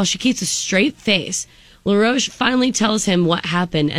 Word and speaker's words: LaRoche: Is it LaRoche? How do you say that? – I believe that's right. – L-A-R-O-C-H-E? – Yeah, that's him. Mm-hmm. Yeah, oh, okay. LaRoche. You got it.